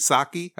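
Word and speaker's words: Psaki: Like this Jen Pensky, Sensky, Saki. I think the P is silent.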